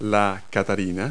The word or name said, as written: ladybug